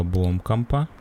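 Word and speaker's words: Blomkamp: Да, а это фильм 2009 года, производства США, а, фильм Нила Бломкомпа Фильм Нила Бломкампа. – Покажи, как это пишется. – Ну, Бломкамп. Нил Бломкамп. Бломкамп.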